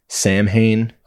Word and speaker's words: Samhain: Now, you may have seen this word before, and you may have heard it in another pronunciation because it's spelled Samhain. Samhain. – Okay. – So there's a band, Glenn Danzig had a band called Samhain. But it's actually pronounced Samhain.